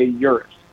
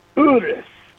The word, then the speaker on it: Urus: The way I try to keep it somewhat clean with the name is to just make sure you understand that the Italians don't say Urus, even though that's what all us unsophisticated Americans say. – Yeah. – To say it properly, it's Lamborghini Urus.